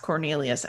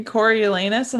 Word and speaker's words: Coriolanus: This is funny, but I just realized it's not pronounced Cornelius as you were speaking. – Oh, yeah. – This entire time I was reading it as Cornelius and you're like saying Coriolanus. And I was like, oh, whoops.